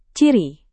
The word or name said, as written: ciri